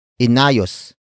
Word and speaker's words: inayos: Word for word it's inayos ni Henry ang upuan.